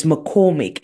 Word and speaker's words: McCormick: So it's McCormick, McCormick.